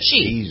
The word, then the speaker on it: Xi: I think, if I'm not mistaken, though, you can correct me if I'm wrong, that there was a conversation about meeting with, is it Xi? I never pronounced his name right. The president of China is Xi. – She. – He's Xi. She.